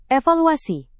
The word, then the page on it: evaluasi: indonesian